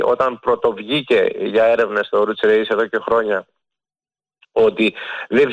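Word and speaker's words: Oruç Reis: Δεν το κάνω για διόρθωση να κάνω τον έξυπνο, απλώς το λέω με την τουρκική προφορά. Γιατί έτσι είναι το όνομα, αλλά όχι ότι είναι λάθος όταν όλος ο κόσμος στην Ελλάδα τουλάχιστον το λέει ο Ρούτς Ρέις. Δεν είναι λάθος, δεν χάθηκε ο κόσμος.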